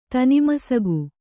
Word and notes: ತೀನ್ಮಸಗು: Kannada

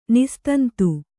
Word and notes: ನಿಸ್ತಂತು: nistantu

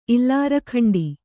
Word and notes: ಇಲ್ಲಾರಖಂಡಿ: kannada

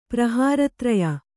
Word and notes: ಪ್ರಹಾರತ್ರಯ: prahāra traya

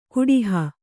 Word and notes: ಕುಡಿಹ: kuḍiha

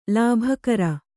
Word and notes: ಲಿಬಿಕಾರ: Kannada

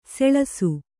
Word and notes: ಸೊಳಸ: soḷasa

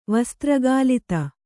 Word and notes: ವಸ್ತ್ರಗಾಲಿತ: Kannada

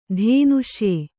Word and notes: ಧೇನುಷ್ಯೆ: kannada

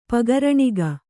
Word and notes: ಪಗರಣಿಗ: kannada; pagaraṇiga

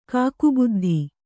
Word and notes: ಕಾಕಬುದ್ಧಿ: kāka buddhi

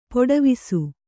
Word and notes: ಪಡಿವೆಸ: paḍivesa

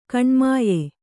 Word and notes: ಕಣ್ಮಾಯ: kaṇmāya

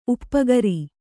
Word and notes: ಉಪಕಾರ: upakāra

